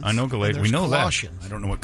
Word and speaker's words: Colossians: Colossians. I've never heard that one before. – Let's hear Google announce it. – It'll say Colossians.